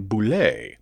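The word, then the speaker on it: Bulette: It's probably a stretch sub uh, Subbullet um, in 5th edition Dungeons and Dragons, it's pronounced Bullet. There is a monster called a Bullet. It's basically like a giant evil uh, armadillo. Uh, although past editions have gone with French sounding Boulet.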